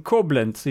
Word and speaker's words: Koblenz: the Schloss Apotheke in Koblenz in Germany.